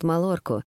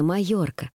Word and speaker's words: Майорка: Наверное, я единственный немец, который не знает Малорку», — признался Матиас между зевками. – «Произносится Майорка, поскольку пишется с двойной буквой «Л», — улыбнулась она.